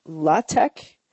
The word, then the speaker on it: LaTeX: What that is it's a module that, if you're familiar with LaTeX, I believe is how you pronounce it. It looks like latex, but it's not pronounced that way because that would be dirty.